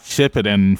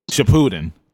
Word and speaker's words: Shippuden: And it's one of those things where, like, in the English world, they want you to pronounce it this way, but the Japanese actually call it Shippuden.